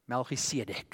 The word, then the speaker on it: Melchizedek: Melchizedek, that's how you say it in Hebrew.